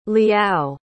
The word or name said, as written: liao